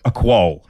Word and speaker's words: quoll: I love coming to the US and watching people try to pronounce this one. It's a quoll. – Quoll. – Yeah, that's it. Well said.